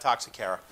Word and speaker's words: Toxocara: No, can't complete its life cycle, and scratch what I just said about Toxocara. I said it right. It's getting late in the day. Toxocara cataea here.